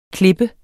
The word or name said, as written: klippe